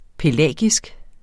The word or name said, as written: pelagisk